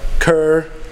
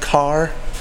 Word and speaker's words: Kerr: But someone told me that K-E-R-R in North Carolinian is pronounced Carr.